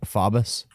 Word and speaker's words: Faubus: F-A-U-B-U-S. How do you pronounce that? – I have to see it. Faubus? – Faubus.